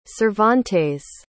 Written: Cervantes

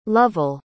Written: lovell

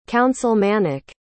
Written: councilmanic

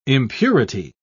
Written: impurity

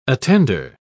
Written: attender

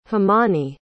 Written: himani

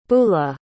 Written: Beula

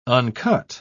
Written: uncut

